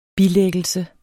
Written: bilæggelse

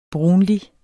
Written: brunlig

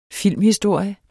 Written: filmhistorie

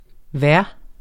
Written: vejr

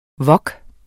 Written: wok

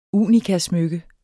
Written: unikasmykke

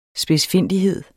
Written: SPIDSFINDIGHED

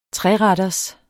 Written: treretters

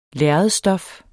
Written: lærredsstof

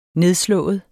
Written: nedslået

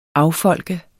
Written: affolke

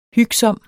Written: hygsom

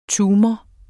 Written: tumor

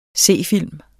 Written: c.

